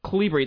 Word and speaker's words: Kolibri: It's Calibri. It's spelled Kolibri.